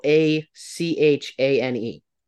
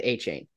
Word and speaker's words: Achane: Now, it is spelled A-C-H-A-N-E, so like A-Chan, but it, everyone pronounced it A-Chain.